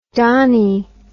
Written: donnie

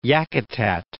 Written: Yakutat Bay

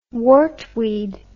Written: wartweed

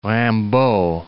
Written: Rimbaud

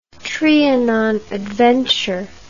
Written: Trianon adventure

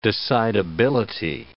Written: decidability

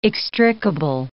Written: extricable

